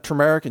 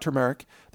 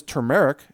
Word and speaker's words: turmeric: And by the way, if you talk about turmeric, there are really two appropriate ways to pronounce it. One is turmeric, as I'm doing, and the other is turmeric. But you cannot say turmeric. It is not a tumor. It's not a tumor root. It's not turmeric. There's an R in there. It's turmeric, or as I say, turmeric. It's just easier to pronounce that way.